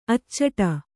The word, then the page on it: ಅಚ್ಚಿಡು: Kannada